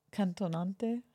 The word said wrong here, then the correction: cantante: How do you say singing? – What? Cantante? – Cantante? – Yeah.